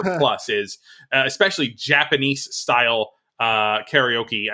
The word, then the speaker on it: karaoke: But so I may pronounce it both ways during the segment. It's generally sort of calcified as karaoke in American English.